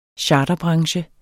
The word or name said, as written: charterbranche